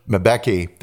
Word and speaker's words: Mbeki: During the presidency of, I'll mispronounce his first name, Thabo Mbeki. Mbeki, that's it, Mbeki.